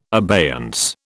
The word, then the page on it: abeyance: British English